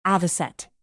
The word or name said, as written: avocet